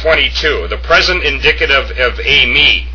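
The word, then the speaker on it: a-me (εἰμί): the epsilon iota is pronounced a, as in ace. It has a smooth breathing comma over the second vowel, and then it has an accent over the iota, and it's pronounced a-me, and it's very important to do that to yourselves, in your minds, at least in your minds or out loud.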